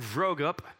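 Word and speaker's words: Vroegop: Mark Vrogup, and he told me how to say his name. It's like throw up, but vro up. So it's Mark Vrogup, and he wrote one called Dark Clouds, Deep Mercy, that was excellent, but I actually liked this one better.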